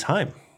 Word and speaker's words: Haim: I think it's Haim. Like Haim. – Mm-hmm. Sounds German. – Yeah, it does. A little bit in the pronunciation. But you're right. It is in doing, again, a little research for this, light research, listeners. It is Haim.